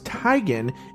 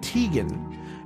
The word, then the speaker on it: Teigen: In a tweet on Sunday, the model, cookbook author, and wife of John Legend clarified that it is actually pronounced Teigen instead of Teigen after a user mentioned that most people are probably saying it incorrectly.